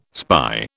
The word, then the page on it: spy: British English